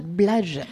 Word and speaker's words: Blige: Se prononce Blage. Ah oui. – Oui, si tu veux la faire à l'américaine.